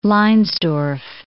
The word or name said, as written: Leinsdorf